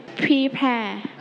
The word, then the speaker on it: prepare: Prepare. – Prepare. Let's check together. Ever say it together. Prepare. – Prepare. – Okay, so we stretch on the second syllable.